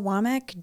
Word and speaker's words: Wammack: Everything is just my name, Rachel Womack, W-A-M-M-A-C-K.